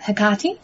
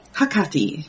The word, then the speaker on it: Hecate: Ready to start telling us about Hecate? – Hecate, yes, or Hecate, as most people are, would recognize her name. – Most people are familiar with that pronunciation.